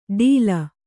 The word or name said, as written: ಟೀಲ